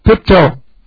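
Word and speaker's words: pipto (πίπτω): P-I-P-T long O, pipto.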